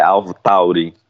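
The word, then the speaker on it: AlphaTauri: É Alpha Tauri, isso.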